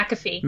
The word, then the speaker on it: McAfee: Is that McAfee? Is that how you say his name? – McAfee. – McAfee.